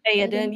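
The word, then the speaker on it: Théoden: Not quite right in the middle, but maybe like a 70 Buddy, 30 Theoden, okay. Is that how you say his name? – Theoden, yeah.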